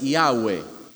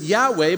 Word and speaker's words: Yahweh: And we traditionally pronounce this Yahweh, but the truth is we actually don't know how to pronounce it.